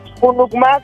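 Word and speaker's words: Hunucmá: Junucumá, como suena como con J. – Sí, pero se escribe con H. Junucumá.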